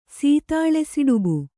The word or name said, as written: ಸೀತಾಳಸಿಡುಬು